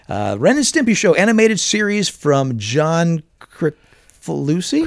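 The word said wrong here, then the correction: Kricfalusi: Chris Falucci. I just found that out recently. Chris Falucci is how it's pronounced.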